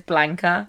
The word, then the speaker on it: Blanca: You know, like the what's the one we were saying wrong for so long? – Oh my god. – The Bianca flight suit. And it's Blanca.